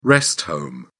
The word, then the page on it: rest home: British English